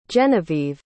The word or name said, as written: jeniveve